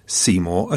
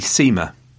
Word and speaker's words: Seymour: Anyway, his friend is Posh, and apparently Seymour should not be pronounced Seymour at all. That it's one of those things like Ralph and Rafe, you know, squished, if that's the technical term. So it should really be Seema.